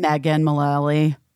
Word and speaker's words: Mullally: And Megan Mullally.